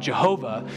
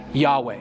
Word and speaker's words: Yahweh: This used to be pronounced Jehovah. So if you ever read an old hymn or an old Bible or an old sermon and you hear someone call God Jehovah, there are they're calling him the same thing that we now think is probably better translated or tra- pronounced as Yahweh.